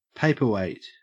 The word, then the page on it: paperweight: Australian English